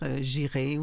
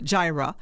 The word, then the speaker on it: Jireh: Le film montre que le Gyra Gospel, il faut dire que ça s'écrit le J-I-R-E-H. Alors, oui. – On est porté à dire j'irai ou j'irai, mais c'est Gyra Gospel Choir.